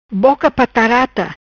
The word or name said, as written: Boca Patarata